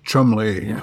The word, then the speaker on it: Cholmondeley: Like, you read something that looks like Cholmondeley and it's pronounced Chumley.